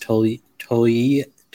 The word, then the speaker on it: Togliatti: I guess it's pronounced Togliatti. The G is like a Y in Italian.